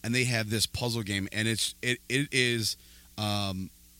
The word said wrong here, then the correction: Mahjong: am I saying it right? – I think it's Mahjong. – Mahjong? – The tile game? – It is Mahjong.